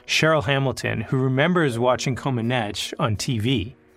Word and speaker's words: Comaneci: At the Summer Olympics in 1976, Romanian gymnast Nadia Comaneci, and by the way, that is how you say it, we fact checked this, her score was perfect.